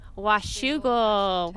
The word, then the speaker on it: Washougal: Washougal, Washington.